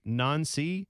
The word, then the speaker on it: Nancy: I know his name is pronounced Nancy.